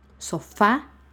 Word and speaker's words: sofá: Sofá, el acento va en la A, en la última sílaba, sofá.